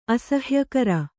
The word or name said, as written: ಅಸಹ್ಯಕರ